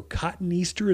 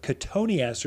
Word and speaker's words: cotoneaster: Another one that's kind of like that is cotoneaster, or cotton easter is how people spell it, but cotoneaster is how you pronounce it.